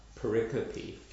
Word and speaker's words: pericope: I've been told, that's how I said it, pericope, and now I was told, no, it's pericope.